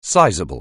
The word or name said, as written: sizeable